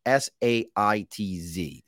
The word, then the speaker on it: Saitz: And Saitz, if you're listening to this, is S A I T Z Saitz. Trust me, because I asked beforehand of everything, because you all know I can't pronounce anything.